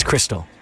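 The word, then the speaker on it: Xtal: However, I've been told by a very helpful listener, it's Crystal. Like Xmas for Christmas.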